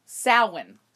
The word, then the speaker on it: Samhain: That's how it's spelled. It's pronounced Samhain.